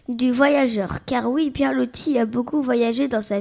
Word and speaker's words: Loti: Et oui, Loti, qui se prononce Roti.